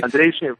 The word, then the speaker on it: Andrei: Did I remember that right? – Andrei, right, perfect pronunciation. You got it.